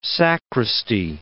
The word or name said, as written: sacristy